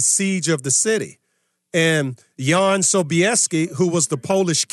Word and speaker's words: Jan: But of Jan Sobieski, spelled J-A-N, it's pronounced Jan, Okay, of that 81,000 troops, 38,000 of them were on horseback.